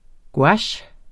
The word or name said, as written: GOUACHERNES